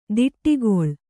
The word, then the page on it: ತೋಟಿಗೊಳು: tōṭigoḷu